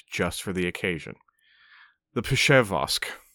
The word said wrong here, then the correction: Przeworsk: Przeworsk, named after the town in Poland where the most important finds were made.